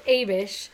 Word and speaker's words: Abish: The pronunciation guide says Abish.